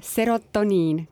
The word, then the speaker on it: serotoniin: osaled Eesti Laulul ja väga äge loo pealkiri, ma proovin nüüd väga korrektselt hääldada, serotoniin, kas nii, eks? jaa, väga õige, kõlas hästi see ei ole väga tavapärane mm, sõna Eestis, mida kasutatakse.